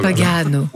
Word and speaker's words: Pagano: Ettore Pagano. – Il y a deux T. Ettore. – Voilà, Ettore Pagano qui a donc remporté... – Pagano.